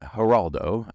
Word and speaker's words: Geraldo: I got to say his name right because it's so easy to say Perdomo, but it's Perdomo. Geraldo, I checked, even though that, that G is not a J in Spanish.